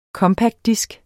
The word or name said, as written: compactdisk